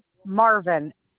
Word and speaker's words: Marvin: Marvin, M-A-R, V as in Victor, I-N.